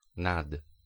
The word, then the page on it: sur: [syʁ]; /syʁ/; /syr/